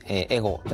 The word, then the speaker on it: 82GO5: まあ、 8-2-G-O-5 と書いて、エゴと読むんですが、もともとは 8 というよりはですね、この循環をイメージした丸円を、 2 つこう重ねて、その重ねたところに未来に向けたこうイノベーションあるんじゃないかというところで、このマークが最初に出来上がったんですね。